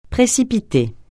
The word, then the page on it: précipiter: francais